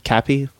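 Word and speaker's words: caapi: I hope I said that right. Kapi, I think kapi. – It's kapi.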